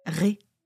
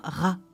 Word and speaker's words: Ré: Ré. Ça s'écrit R, E accent circonflexe. On l'appelle parfois Ra aussi.